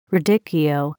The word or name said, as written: radicchio